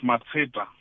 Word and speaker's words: Matsheta: It's Matsheta. It's a Pedi surname from the far north of Limpopo.